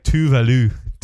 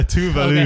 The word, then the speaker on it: Tove Lo: It's that and Tove Lo. I think it's pronounced like Tuvalu. – Oh, is it? – Yeah, Tuvalu.